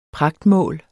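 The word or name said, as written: pragtmål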